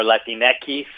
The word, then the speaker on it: Latinx: Yeah, Latinx or Latinx, if you want to pronounce it in the Spanish version, is...